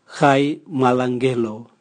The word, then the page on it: gai mwalangelo: Raga